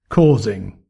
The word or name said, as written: causing